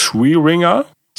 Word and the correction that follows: Swearingen: Swearinger? – Mhm. – Swearingen. Swearingen. Swearingen.